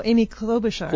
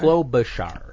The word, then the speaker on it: Klobuchar: How do you pronounce her name? Kolblark? – Oh, Amy Klobuchar. – Klobuchar.